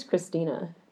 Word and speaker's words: Cristina: My middle name is Christina, and it's spelled C-R-I-S-T-I-N-A.